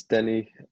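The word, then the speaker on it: Deni: Yeah, it's Denny, which usually it's not a common name in Israel. Usually Israelis call it Danny, Danny is very common. But Denny, it's not so common at all.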